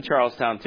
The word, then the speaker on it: Charlestown: Is that how you say it, Charlestown? Charleston? You guys don't say the town? Okay, all right, I was just checking to make sure. Keeping a day, I went to Charlestown too.